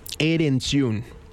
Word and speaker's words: Erentxun: Esta luz nunca se apagará de Michael Erentxun. Ya lo pronuncia bien. Erentxun.